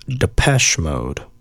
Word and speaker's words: Depeche Mode: Okay, so apparently it's Depeche Mode. I didn't know that, but yeah, that's how you pronounce it for anyone out there who was wondering.